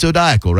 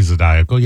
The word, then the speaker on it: zodiacal: It might draw in the people who might be interested in zodiacal radio programs. – Yeah. – Not Zydeco radio programs, but Zodiacal. No, it's Zodiacal, right? I've got that right. – Zodiac. I would say Zodiacal.